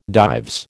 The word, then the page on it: Dives: American English